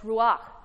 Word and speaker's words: ruach: It's pronounced ruach.